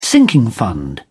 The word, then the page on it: sinking fund: British English